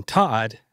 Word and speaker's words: Todd: So my name being Todd, rhyming with pod, was perfect.